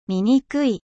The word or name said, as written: laid